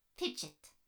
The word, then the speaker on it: F.H.I.D.G.D.: My name is F.H.I.D.G.D. pronounced Fidget. I am the friendly HoloNet integrated digital guided device. But again, I like very much to be called Fidget.